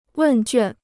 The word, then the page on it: 问卷: wèn juàn